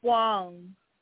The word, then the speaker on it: Huang: I'm trying to remember, how you pronounce H-U-A-N-G? Huang? – Wong. – Okay. So Wong.